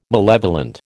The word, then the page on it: malevolent: American English